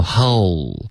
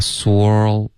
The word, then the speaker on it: whole: whole soul 不要发 swirl OK 刚刚变成别的发音了是一个双元音、